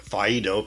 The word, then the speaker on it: Phaedo: In his writing, Phaedo, P-H-A-E-D-O, that is spelled, Phaedo, he epitomized his thoughts about death.